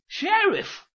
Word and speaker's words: Sheriff: 哦哦，想起来了，想起来了，叫雪瑞夫，Sheriff。 – 对啦，就是他。这雪瑞夫原是由两个古英文字合起来念成的，头一半叫 Shire，后一半是 Reef。